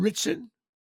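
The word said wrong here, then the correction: Ritchson: Richson, that's how you say it? – Yeah, but I said big Christian.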